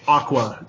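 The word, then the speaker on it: Aqours: because it's spelled Accors or Accors. It's Aqua, like the fucking Barbie girl group.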